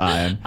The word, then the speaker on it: Ian: Spelled Ian, but pronounced Iron.